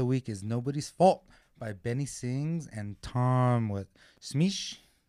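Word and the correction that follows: Misch: Mish. Mish. – I can never say his name. There's no S at the beginning.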